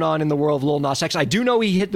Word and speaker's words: Lil Nas X: I pronounced it like Nas, like the pop star. – So all right, just got to back it up. It's just Lil Nas X. Just let it flow.